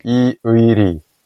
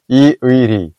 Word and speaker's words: Lee Ui-ri: So so he's spelling it E-U-I, and that's an impossible word to pronounce in English, I think. – Yes. – And even for Korean speakers, like I said earlier, that's a tongue twister. So Lee Ui-ri.